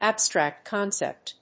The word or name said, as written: abstract concept